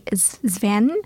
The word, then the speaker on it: Zviane: So I have one, and it's actually a graphic novel called Going Under by Zven. I'm actually saying her name. But it's spelled Z-V-I-A-N-E, and that's the pen name of Sylvie Anne Menard.